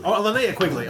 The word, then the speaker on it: Linnea Quigley: Yep, Leanna Quigley. – Linnea Quigley.